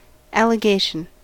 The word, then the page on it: allegation: American English